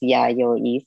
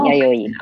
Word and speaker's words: Yayoi: It's yeah, just it's yayoi, so it's three syllables. – Mm-hmm. Oh, yeah, is yayoi? – Mm-hmm. Oh, yayoi.